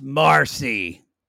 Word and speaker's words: Mercy: It's spelled Mercy, but it's pronounced Marcy.